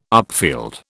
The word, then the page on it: upfield: British English